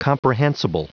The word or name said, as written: comprehensible